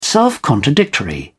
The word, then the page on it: self-contradictory: British English